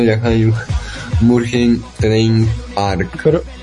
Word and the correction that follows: Mugen Train: Mugen Train.